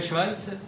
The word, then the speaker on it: Schweitzer: No to se vyslovuje Švajce.